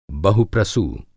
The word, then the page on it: बहुप्रसू: Hindi